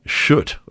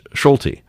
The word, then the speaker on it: Schulte: The headline is that, and by the way, they called him Shoot or something. You know what that sounds like on Democracy Now, but we pronounce it, and that's kind of the way it is, Shuet. But we know in Michigan it's Schulte.